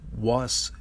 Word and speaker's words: wascoite: wascoite. And I might be saying that wrong too. It's W-A-S-C-O-I-T-E. And that was based in the town of Wasco, Oregon.